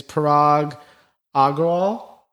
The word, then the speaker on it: Parag Agrawal: The new CEO, his name is Parag Agrawal.